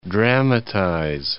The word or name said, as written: dramatize